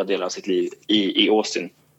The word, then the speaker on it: Austin: Eller nu uttalar jag så svensk, när jag snackar med en svensk liksom, i Sverige säger vi typ Austin. De säger inte å där. Vi uttalar nästan som att stavas med ett å. Det är med Austin.